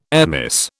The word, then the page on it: airmiss: British English